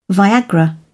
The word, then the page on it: viagra: British English